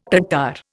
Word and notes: Tatar: Indian

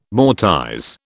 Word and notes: mortise: British English

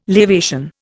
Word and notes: lavation: Indian

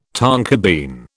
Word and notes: tonka bean: British English

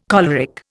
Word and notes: choleric: Indian

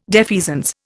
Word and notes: defeasance: Indian

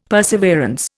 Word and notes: perseverance: Indian

